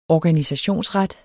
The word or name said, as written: organisationsret